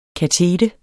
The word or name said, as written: kateter